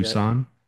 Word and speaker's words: Tucson: They're going to Tuscan, Tuxkin. How do you say that? – Tucson.